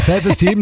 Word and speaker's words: Self Esteem: The Offspring, Self Eastern. – Ja, die Aussprache ist ein bisschen... – Team is Team. – Ja. Ja, ist richtig. – Ja, Self Esteem nehmen wir auch.